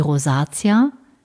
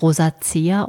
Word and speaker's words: Rosacea: Die Rosacea, teilweise auch als Rosacea ausgesprochen, hat noch viele weitere Bezeichnungen.